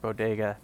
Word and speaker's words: bodega: She was doing a speech in Texas, and she said something about, like, diversity, like the boguetas in New York and the tacos of San Antonio. And people in New York apparently didn't care that she mispronounced bodega,